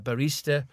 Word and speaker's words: barista: So numerous different bit jobs in bar work, in being a barista, and not a barrister, that's a barista, that's somebody that makes coffees, just in case you thought I mispronounced it.